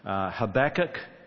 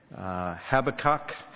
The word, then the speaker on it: Habakkuk: We're not quite sure how it's pronounced. Habakkuk, Habakkuk, Habakkuk. Just don't call it Habakkuk, because he's not a kook.